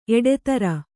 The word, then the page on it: ಎಡೆತರ: kannada